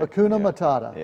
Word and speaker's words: Hakuna Matata: Hakuna Matata.